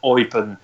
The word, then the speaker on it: Eupen: Is it UPenn? Yeah. Forgive me if I've pronounced that wrong. – It's in the German part of Belgium, so it's Eupen.